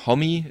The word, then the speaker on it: Homme: Das ist natürlich auch mal die Sache, wie spricht man den Namen aus? Ich glaube, Homme ist, ist so das, wo sich die meisten drauf geeinigt haben.